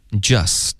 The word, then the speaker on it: just: 好，这句话开头 you've just，这个 just 的 t 的音呢，我们就彻底的省略，这个字太常见了，然后一般 t 呢就彻底的省，干脆的停顿都不需要。